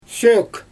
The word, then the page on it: sʲøøk: Xyzyl